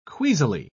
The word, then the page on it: queasily: British English